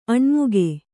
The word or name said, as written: ಆನೆಮುಗ